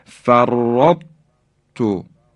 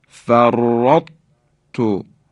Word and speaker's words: فرطت: به این خاطر بایستی دقت کنیم و اینطور تلفظ کنیم فرط تو، فرط تو، اثری از درشتی حرف تا باقی مونده.